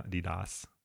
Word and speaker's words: Adidas: Is that how we pronounce it now? Adidas, long A.